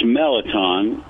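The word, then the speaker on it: mirliton: It's pronounced Melaton. If you're actually from Louisiana, it would be French, and so there wouldn't be an R sound. It's a S sound,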